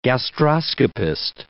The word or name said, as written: gastroscopist